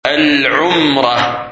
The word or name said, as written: Umrah